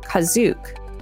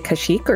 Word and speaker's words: Kashyyyk: In the Holiday Special, the first mention of the planet carries the pronunciation Kazook. Oh.